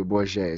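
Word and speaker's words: Bourguet: Bourget, it's Bourget. He doesn't do the French pronunciation.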